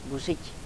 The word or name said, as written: buzic